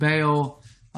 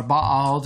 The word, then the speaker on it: Baal: And that's the one in which, you know, the prophets of Baal or Baal, depending on your pronunciation, Elijah challenges them on Mount Carmel, this big showdown.